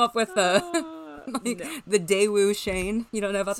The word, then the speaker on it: Cheyne: But in 1988, his parents pulled him and his younger brother, Shane, or Cheyenne. – I do think it's Shane, but it's spelled C-H-E-Y-N-E.